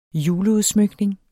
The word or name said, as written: juleudsmykning